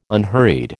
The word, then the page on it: unhurried: American English